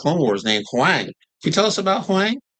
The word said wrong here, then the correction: Huyang: Did I pronounce that right? – Huyang. – Huyang. Okay. – It's pronounced how Huyang. It looks.